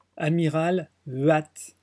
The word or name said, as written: huate